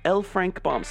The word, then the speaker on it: L. Frank Baum: All right, here we go with L. Frank Baum.